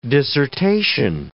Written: dissertation

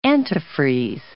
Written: antifreeze

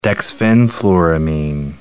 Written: dexfenfluramine

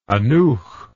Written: aneuch

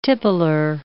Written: tippler

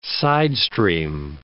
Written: sidestream

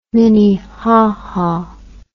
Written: minnehaha

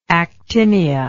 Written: actinia

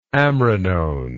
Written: amrinone

